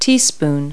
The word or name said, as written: teaspoon